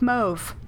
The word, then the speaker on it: mauve: And gray, or there are so many ways to pronounce this word. Let's go with mauve, because that's how the Met pronounces it.